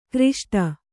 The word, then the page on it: ಘೃಷ್ಟಿ: ghřṣṭi